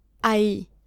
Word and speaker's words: ahí: So they sound very similar, don't they, those two words? So don't confuse them. So ahí is there is, and ahí, which is a slightly different pronunciation, is there.